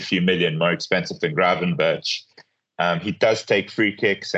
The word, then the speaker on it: Gravenberch: But yeah, if they both start, I'd rather take the cheaper Gravenberch, I would say. – That's a tremendous bit of pronunciation, by the way. I don't think anyone's been on this podcast and got his name right.